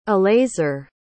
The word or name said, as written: elazer